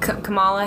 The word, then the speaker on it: Kamala: Kamala Harris just dropped out of the election. – Oh, really? – Yeah. – You pronounced her name right, I think.